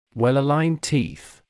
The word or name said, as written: well aligned teeth